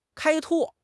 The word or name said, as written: 开拓